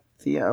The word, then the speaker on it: Thea: Okay, the Themed Entertainment Association, T, has announced the group of Thea Awards. Did I say that right? – Yeah. – Thea, okay.